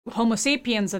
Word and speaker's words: Homo sapiens: Today, Homo sapiens are the only only species within the Homo genus. Am I saying that correctly? Left? – Yes, you are.